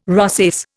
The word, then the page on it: rosace: Indian